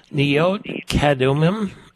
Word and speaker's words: Neot Kedumim: Neot Kedumim, is that how you pronounce it? – Yes, okay.